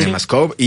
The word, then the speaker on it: CinemaScope: Sí lo pronuncié bien, ¿verdad? Sí. CinemaScope.